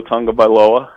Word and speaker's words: Tagovailoa: I know the guy that's getting all the buzz in the offseason will be Tua Tagovailoa.